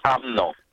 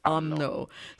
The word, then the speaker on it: UMNO: Do you say UMNO? Is that how you say it? – Yes, that's right. It's pronounced as UMNO. – UMNO.